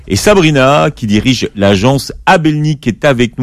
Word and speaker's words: Abelni: Voilà, et on a enlevé le qaf pour plus de facilité à prononcer. Donc c'est Abelni, A-B-E-L-N-I.